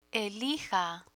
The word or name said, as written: elija